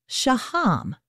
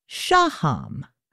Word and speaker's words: Shaham: Gil pronounces his last name Shaham, but Orly pronounces her last name Shaham. Both are apparently correct and just up to the choice of the speaker, so the two siblings have chosen different places to put the emphasis on the syllable.